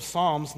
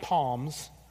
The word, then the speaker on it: Psalms: And we're going to be looking at the book of Psalms, not Palms. The P is silent, so those of you who are grammarians, you need to share that with other people.